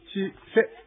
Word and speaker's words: チセ: 先ほどですね、アイヌ語ワンポイントレッスンで、私がチセというやつの発音を、どうなのかなってちょっとうやむやにしたんですけども、チは低いんですね。チセというふうになります。